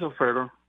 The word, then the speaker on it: Alfredo: My name is Alfredo. – Alfredo. Anna, did I say that right? – Yeah. – Okay. – Yes, you know, like Alfredo sauce?